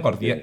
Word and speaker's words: gordiano: lleva al nudo jordiano, ¿lo he dicho bien? – Gordiano, sí, eso. Gordiano.